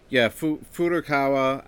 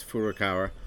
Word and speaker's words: Furukawa: Furukawa. I'm not looking at the name right now, but I know it's Furukawa.